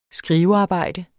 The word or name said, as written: skrivearbejde